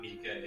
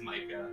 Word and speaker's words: Micah: The whole first thing they say is his name is not Mika. It is Micah.